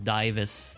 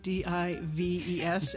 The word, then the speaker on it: Dives: he sound it looks like he pronounces Divas, uh, Divas and Lazarus. – Yes, it's tripping me up because Divas is spelled D I V E S, and my brain is going dives, yeah, dives.